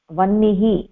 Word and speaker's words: वह्निः: तत्र हकारस्य अनन्तरं नकारः अस्ति चेत् यथा वह्निः इत्यत्र वह्निः इति नास्ति वह्निः. तत्र नकारस्य उच्चारणं प्रथमं भवति अनन्तरं हकारः.